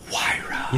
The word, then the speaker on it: Huayra: Is that how you pronounce it? – Yeah, that is how you pronounce it. I said it correct. – Say it. – Huayra. – Nice. – Huayra.